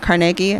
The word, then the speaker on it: Carnegie: They're in Carnegie.